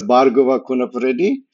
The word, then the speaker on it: Bhargava Kunapareddi: My name is Bhargava Kunapredi.